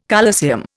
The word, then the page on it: colosseum: Indian